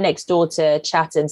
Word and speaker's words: Chatham: I grew up in a town called Chatham, except no one pronounces the T, so you call it Chatham.